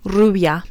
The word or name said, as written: rubia